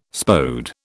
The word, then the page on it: Spode: British English